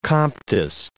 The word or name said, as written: Comtist